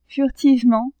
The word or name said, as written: furtivement